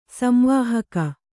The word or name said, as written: ಸಂವಾಹಕ